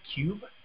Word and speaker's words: Ciub: And then we, Ekdom came over, and we played two games of Cube. – What's that? – It's spelled C-I-U-B.